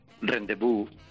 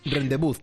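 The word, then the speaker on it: rendez-vous: Rendezvous. – Rendezvous, tal cual, ¿no? – Sí, rendezvous. Sí, rendezvous, perfecto. ¿Qué significa eso? – ¿No? Es una cita. Una cita exótica. Exotic rendezvous.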